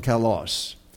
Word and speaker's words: kalos: The accent is on the second syllable or the third syllable, the final syllable there. Agathos and kalos.